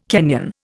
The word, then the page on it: Kenyan: Indian